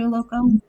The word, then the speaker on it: Uloko: Am I saying that right, Dr. Uloko? – Yes, that was perfect.